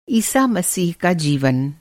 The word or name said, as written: ईसा-मसीह